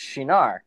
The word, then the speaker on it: Cynar: And I found a website and they it's pronounced Shinar.